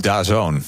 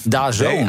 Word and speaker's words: DAZN: DAZN. – DAZN.